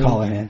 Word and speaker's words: Colohan: Colohan.